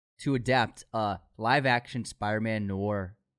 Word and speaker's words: noir: Congratulations on pronouncing Noir. – I took my time with it. I took my time. – Noir. – Noir. – There you go, man.